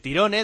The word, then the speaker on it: Tyrone: Tyrone, del Huesca, se pronuncia en inglés, sería Tyron.